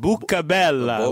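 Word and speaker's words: Boccabella: The Montreal announcer pronounced it the Italian way. He said Bucabella, which is beautiful mouth.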